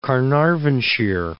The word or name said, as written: Caernarvonshire